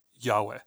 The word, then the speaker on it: Yahweh: Hebrew is written without vowels, so when we try to pronounce YHWH, we end up saying Yahweh.